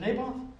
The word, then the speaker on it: Naboth: Naboth was it Naboth? I say that right? – Yep.